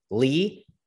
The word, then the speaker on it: Lea: Today, we are talking to, take note on the pronunciation, Lee Maurer.